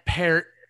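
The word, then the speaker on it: Peart: Matt Pert, as it is correctly pronounced, I learned today, as we've been saying, Pert, did an excellent job.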